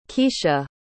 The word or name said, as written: Kecia